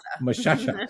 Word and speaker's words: Moshasha: Moshasha.